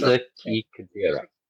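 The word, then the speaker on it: Sasaki Kojirō: am I even saying his name right? Sagiri? – Sasuke. – Sasuke. – Sasuke Kajira. Kajira. – Kajira, okay. Sasuke.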